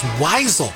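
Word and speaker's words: Wiesel: thanks so much to Mike in Arizona, telling me that the pronunciation of the author we were talking about earlier is Weisel, Eli Weisel.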